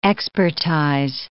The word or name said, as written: expertize